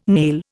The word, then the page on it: nail: Indian